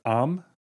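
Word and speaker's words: am (עם): In Hebrew, the word nation is spelled the same as the word with, pronounced am and im.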